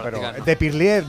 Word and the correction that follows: pilier: ¿no? ¿Se dice así? Parlier, Perlier. – El que patea. No lo sé. ¿Cómo? – Pilier. – Bueno, el capitán... – De Pilier.